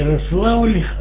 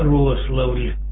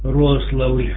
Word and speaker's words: Рославль: Город Рославль, да, как я понял, Рославль, да, прием? – Ну, не Рославль, а Рославль. Ударение на О. Рославль, на приеме.